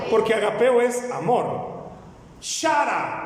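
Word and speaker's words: Chara (χαρά): O mejor dicho, ¿cómo se escribe gozo en, el griego? Chara. Diga conmigo, chara. – Ahí está, chara. ¿Cómo es? Chara.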